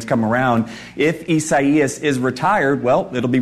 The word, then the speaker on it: Isaias: Isaias, which I believe is another way of saying Isaiah. So again, Isaias.